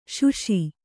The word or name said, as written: ಸಾಸ